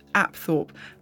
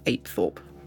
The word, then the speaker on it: Apethorpe: According to Wikipedia, Apethorpe is pronounced Apthorpe, but I'm not sure where they've got this idea from, unless it's just a pronunciation that's fallen out of use. I've certainly never heard any locals call it Apthorpe, and the tour guides didn't use that pronunciation either, so I shall continue using the local pronunciation of Apethorpe.